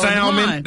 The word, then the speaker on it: salmon: Salmon. That's salmon. – Salmon has that, well, that L is supposed to be silent, ain't it? – Kind of, sort of.